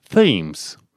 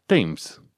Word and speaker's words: Thames: În engleză, în mod normal, tu ai citit Tamisa ca fiind Thames, dar de fapt este Thames. Nimeni nu pronunță Th, TH-ul ăla, știi? Zice T, Thames.